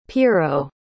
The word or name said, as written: pirro